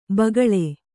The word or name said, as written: ಭೂಗೋಳ